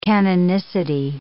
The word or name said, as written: canonicity